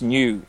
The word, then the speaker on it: nu: depending on how you want to pronounce it, but it's nu is the proper pronunciation because it's a Greek letter for number.